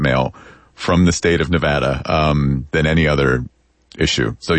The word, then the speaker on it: Nevada: Because a lot of politicians would say Nevada. – Wrong. – And that is wrong. – It is Nevada. And I had to train myself to say Navapple so I could pronounce the A right.